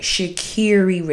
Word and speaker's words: Sha'Carri: I make sure I pronounce her name right. Sha'Carri Richardson.